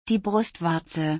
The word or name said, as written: die Brustwarze